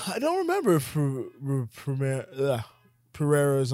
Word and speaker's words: Pereira: Andreas Pereira. Am I saying that right? – Yeah.